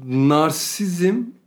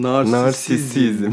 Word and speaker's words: narsisizm: Abi narsizm değilmiş aslında onun telaffuzu. – Narsisizm. Narsisizm. – Narsist değil, narsisist.